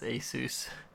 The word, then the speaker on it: Asus: I'm looking up, I was actually looking up pronunciation, and it is Asus.